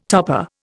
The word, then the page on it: topper: Indian